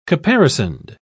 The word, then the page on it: caparisoned: British English